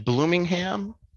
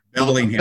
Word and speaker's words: Bellingham: from, is it Bloomingham? Bloomingham, Washington, am I saying that right? I'm way off, aren't I? – Bellingham.